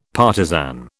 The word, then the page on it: partisan: British English